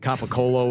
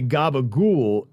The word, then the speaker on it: capicola: It's spelled capicola, but if you're Italian, you say gabagool.